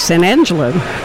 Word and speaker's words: San Angelo: Is that pronounced right? – That's correct, San Angelo.